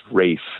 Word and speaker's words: Ralph: his name is spelled Ralph, but it's actually pronounced Rafe.